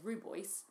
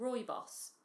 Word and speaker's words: rooibos: And I assumed it because I thought, the way I used to say it is rooibos. I'd assume rooibos meant red bush, and that was just how it was, which is true. But apparently you pronounce it rooibos, so rooibos tea, which I need to get into my head and get myself saying.